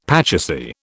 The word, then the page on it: pachisi: British English